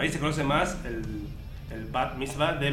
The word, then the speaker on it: Bat Mitzvah: La película que ya está en el catálogo de Netflix es No estás invitada a mi Bat Mitzvah. Creo que lo dije bien. – Sí. – No dije Bar Mitzvah porque no se dice Bar Mitzvah. Pero nosotros a veces lo decimos así.